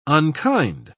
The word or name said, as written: unkind